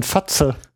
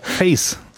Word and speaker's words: FACE: also, wenn es ausgesprochen Englisch ist, muss es in der Abkürzung wohl auch Englisch sein und dann einigen wir uns mal auf Face.